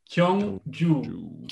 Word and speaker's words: Gyeongju: So who picked you up when you came to, how do you say it again? Gyeongjang? – Gyeongju. – Gyeongju. Gyeongju.